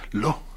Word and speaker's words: Law: pero en la era moderna fue ese Lo, se apellidaba así, Lo. Se escribe L-A-W.